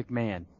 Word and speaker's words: McMahon: Um, they have a guy like uh, you know, then Mike, Mike and Ben Mc, Mac, uh, is it McMahon or McMahon? – I think it's McMahon. – McMahon. That's what I thought.